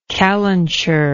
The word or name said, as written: calenturish